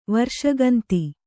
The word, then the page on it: ವರ್ಷಗಂದಿ: varṣagandi